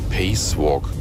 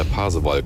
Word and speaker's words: Pasewalk: Ich hatte früher mal einen Kollegen, der immer gesagt hat, ich bin durch Pacewalk gefahren. Lange, lange habe ich überlegt, was er damit meinte, bis wir immer drauf kamen. Das ist ja Pasewalk.